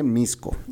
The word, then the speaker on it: Mixco: Mixco se escribe. Mixco. Y se dice Mixco.